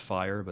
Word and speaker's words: FHIR: One of them is pronounced FHIR, but it's spelled F-H-I-R, that are being looked at that we all probably need to collaborate on to make the next wave, right?